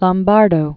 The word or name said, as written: Lombardo